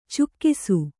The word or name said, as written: ಚೆಕ್ಕಿಸು